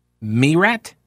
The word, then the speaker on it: Meyrat: Well, as I mentioned earlier, at the Federalist, Auguste, it's August with an E at the end of it. How would you pronounce that? Auguste, M-E-Y-R-A-T, Merat.